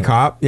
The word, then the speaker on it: Kopp: Yeah, definitely Kopp. Yeah, that's how you say it. It's a German word.